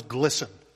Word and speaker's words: glisten: White Christmas has 3 silent T words. Christmas, listen, and this verb that follows the treetops. – What is glisten? – Correct.